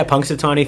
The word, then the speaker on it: Punxsutawney: according to the zoo, as opposed to Punxsutawney Phil. Did I say that right? – Yeah, Punxsutawney Phil.